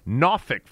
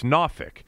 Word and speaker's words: Norfolk: They played in Norfolk, Virginia, which is exactly the way it's pronounced by people who are from down there. It's not Norfolk. It's not Norfolk. It's Norfolk.